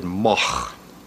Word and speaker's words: Moog: Well, pronouncing M-O-O-G. It's originally a Dutch name. This goes back, you know, a thousand years or so. Uh, and in, in, Holland, you would pronounce it Moch.